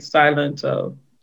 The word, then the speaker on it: Silento: Is it Salento or Silento? Nobody. – If I recall Whip Nene correctly, it started off with them saying Silento.